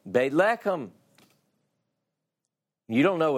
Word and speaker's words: Beit Lechem: If I say to you Bethlehem, or properly pronounced in Hebrew, it's Beit Lechem.